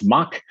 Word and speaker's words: MACH: He is both the Chief Product Officer of Commerce Tools and co-founder and chairman of the MACH Alliance, pronounced MACH.